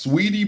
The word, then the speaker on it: Saweetie: Sweetie, I think her name is pronounced sweetie, but it spells salty.